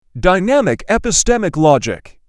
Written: Dynamic epistemic logic

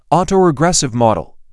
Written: autoregressive model

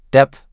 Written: Depth

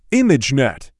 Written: imagenet

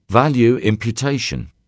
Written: value imputation

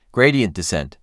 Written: Gradient descent

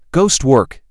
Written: ghost work

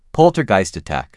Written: poltergeist attack

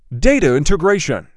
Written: Data integration

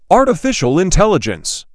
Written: Artificial intelligence

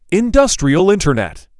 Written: Industrial Internet

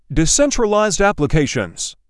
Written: Decentralized applications

